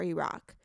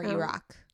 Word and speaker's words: Iraq: Like, it hit me that when someone experiences a tragedy, let's say in Iran or Iraq, pronounced Iran or Iraq. – Oh, okay. Sorry.